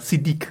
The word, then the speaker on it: Siddiq: Ich glaube, ich habe davor mal Siddiq gesagt, aber Siddiq sagt er ja, äh, in der Aussprache.